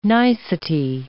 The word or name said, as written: nicety